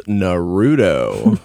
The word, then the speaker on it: Naruto: I believe it's pronounced Naruto.